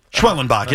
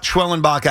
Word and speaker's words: Schwellenbach: Oh, that's how you say his name. – Schwellenbach, yes. – Yeah, I needed to at least say it, right? So now you've got Schwellenbach out of the game,